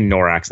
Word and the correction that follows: Norath: That's not right. Norath. I was close. I was like one syllable off.